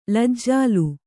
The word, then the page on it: ಲಜ್ಜಾಲು: lajjālu